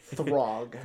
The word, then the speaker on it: Throg: But yeah, that's basically Throg. – Okay, so we have. – Wait, how'd you spell it in the thing? – T-H-R-O-G. – Okay. – Throg.